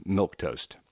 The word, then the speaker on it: milquetoast: Milquetoast, first of all, it's spelled in a funky way. It's milquetoast, but pronounced milquetoast.